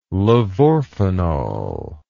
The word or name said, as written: levorphanol